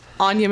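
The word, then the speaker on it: Anja: I can be found at Anya Monique on Instagram, and my name is spelled weird. It's A-N-J-A.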